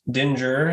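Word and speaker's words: dingir: This pictograph, pronounced Dinger, has many translations depending on its use.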